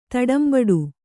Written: ತಡಂಬಡು

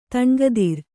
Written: ತನುಗಾತ್ರ

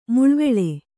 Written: ಮುಳ್ವೆಳೆ

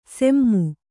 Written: ಸೆಮ್ಮು

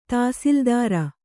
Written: ತಾಸಿಲ್ದಾರ

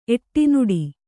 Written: ಎಡೆನೋಡು

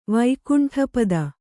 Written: ವೈಕುಂಠಪತಿ

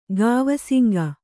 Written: ಗಾವಸಿಂಗ

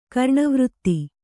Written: ಕರಣವೃತ್ತಿ

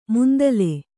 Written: ಮಂದೀಲ

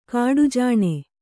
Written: ಕಡುಜಾಣೆ

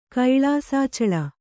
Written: ಕೈಳಾಸಾಚಳ

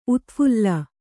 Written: ಉತ್ಫಲ

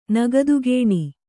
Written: ನಗದುಗೇಣಿ

